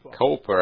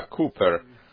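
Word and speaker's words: Cowper: Много лет назад у меня была привилегия побывать в английском городе Оуни и в доме Уильяма Купер, не Коупер, а Купер. Вот как мы произносим это имя.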